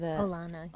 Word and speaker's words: Olana: Like, I loved, is it pronounced Olana? – Olana, yes.